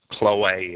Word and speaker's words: Chloe: Chloe is the way I would pronounce the Greek form of the word.